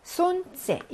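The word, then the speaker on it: 손째 (солнце): 손째. 얘는 요기 발음이 밑줄 그어진 건 처음이고요. 무음으로 유명하죠. L 발음이 안 나서 손째라고 있습니다.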